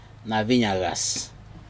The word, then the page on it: naciN acas: Aneityum